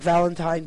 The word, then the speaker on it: Valentin: And her husband was Pierre Valentin, spelled like Valentine, but without the E, Valentin.